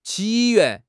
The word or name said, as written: 起源